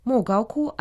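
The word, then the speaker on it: Могао-ку (莫高窟): Могао-ку означает «пещеры в высокогорном пустынном месте». Мо означает «пустыня», произносится в четвертом тоне. Гао – это «высокий», произносится в первом тоне.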